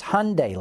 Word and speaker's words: Hyundai: Philip Stubblefield says, please, someone help John pronounce the name Hyundai correctly. As their own commercials have said over a year, it's Hyundai, like Sunday.